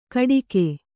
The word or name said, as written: ಕಡಿಗೆ